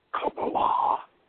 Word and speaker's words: Kabbalah: K-A-B-B-A-L-A-H, pronounced Qabalah, refers to a system of Jewish mysticism developed in medieval Southern Europe. C-A-P-A-L-A is a Christian version of it,